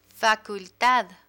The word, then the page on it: facultad: Spanish